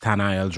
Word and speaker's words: Tanael: Well, my actual name is pronounced Tanael Joachim.